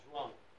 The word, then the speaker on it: João: Yeah, João. – Yeah. How do you say the end? Is it almost like an M? – It's, yeah. – M or N? – N. It's more like an N.